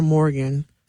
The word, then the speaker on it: Morgan: How can they give your Facebook so they can My Facebook is Jatun Morgan. – Spell that. – J-A-T-A-U-N, last name Morgan, M-O-R-G-A-N.